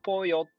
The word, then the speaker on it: pollo: そう、わからない人向けに伝えると、その、ポヨって、 POLLO って書くんですけど、その LLO をよって読んだり、ジョって読んだり、まあ、しょって読んだりっていうのが、その国ごとによって違うんですよね。